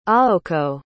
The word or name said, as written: aoko